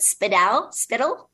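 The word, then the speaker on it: Spiddal: how do you pronounce it? Spittle.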